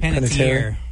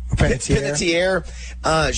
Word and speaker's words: Panettiere: Panettiere. – Panettiere.